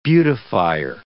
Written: beautifier

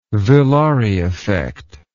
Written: villari effect

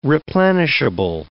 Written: replenishable

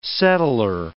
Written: settler

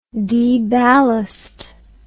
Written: deballast

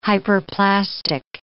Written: hyperplastic

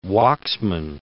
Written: Waksman

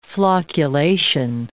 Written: flocculation

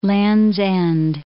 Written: Land's End

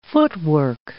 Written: footwork

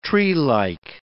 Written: treelike

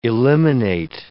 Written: eliminate

